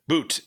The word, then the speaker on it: But: Is it Boot? – Boot, yes. – Yeah, yeah. – Yes, spelled butt, but pronounced boot.